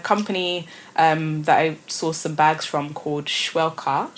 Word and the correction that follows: Shweka: They are, sorry, I pronounced that wrong. Shweka.